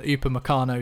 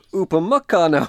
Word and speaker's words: Upamecano: I think Fabrizio Romano, you mentioned on one of last week's podcasts, that he suggested that Upamecano is going to stay at RB Leipzig. – Pronounce it right, please. Upamecano.